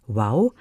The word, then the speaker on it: Woow: W-O-O-W geschrieben, ausgesprochen Wow. Der Kinderbuchverlag wurde im September 2016 gegründet und ist ein Imprint des Atrium Verlags.